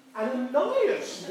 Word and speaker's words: Ananias: Ananias.